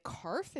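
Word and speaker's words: Karpf: So this all started because this guy named David Karf, is that his name? – I think that's right. – Okay. I didn't know if we pronounced that P or not. That would be wild if we had to.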